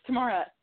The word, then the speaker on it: Tamara: Also, it's Tamara.